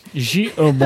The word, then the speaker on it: J. Herbin: I think it's J. Herbin. – J. Herbin.